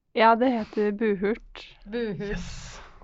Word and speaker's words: Buhurt: Buhurt. Bare tenk på butthurt uten T. – Ja, Behørt. Det, det er forklart.